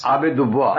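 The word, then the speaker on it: Abbé Dubois: It is A-B-B-E-D-O-U-B-O-U-I-S. – Oh, Dubois. – Abe Dubois.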